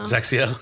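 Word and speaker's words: XXIO: It took me a year to figure out how to pronounce that brand name, though. – Zaxiel? – Yeah, I mean, it doesn't look good. X-I-O. Like, yeah, where'd you get the Z from? – Yeah, I don't know.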